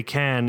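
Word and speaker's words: Caen: I worked on a documentary with World War II archive footage that is playing at the Memorial de Cannes in Cannes, France. That's C-A-E-N, not C-A-N-N-E-S. It's pronounced the same for some reason.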